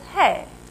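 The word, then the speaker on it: hey (ה): Now, you pronounce that little funny digit as hey.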